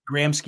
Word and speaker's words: Gramsci: Gramsci. I say Gramsci, yeah.